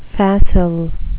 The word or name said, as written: facile